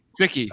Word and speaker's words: Cichy: Jack C-I-C-H-Y. – Sicky, yeah.